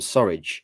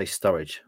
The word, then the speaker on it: Surridge: Sam Surridge. I just didn't want to say Sturridge when I say his name.